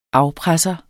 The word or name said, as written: afpresser